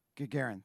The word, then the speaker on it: Gagarin: On April 12th in 1961, a Soviet cosmonaut by the name of Yuri Gagarin, I'm sure I'm saying that wrong. Do you know? Gagarin? Gagarin, thank you.